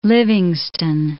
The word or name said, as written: Livingstone